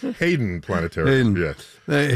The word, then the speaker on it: Hayden: Do you say Hayden or Haydn? – Hayden. Haydn is the composer.